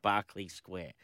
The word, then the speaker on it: Berkeley Square: Now, I'm hearing that this is the way Danny O'Sullivan wants to pronounce it, Barclay Square, even though it looks like it's Berkeley Square.